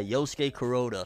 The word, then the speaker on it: Yosuke Kuroda: So shout outs to this dude, Yosuke Kuroda. Uh, am I saying it right? Yeah, Yosuke Kuroda.